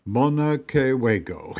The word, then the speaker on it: Monacoago: but called by the Indians Monacoago, pronounced Monacoago.